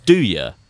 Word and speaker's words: andouille: It's pronounced "do ya." So I was like, "Oh, okay, that makes sense. The N is silent, and you don't pronounce a J. You pronounce it as a Y."